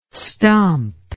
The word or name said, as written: stomp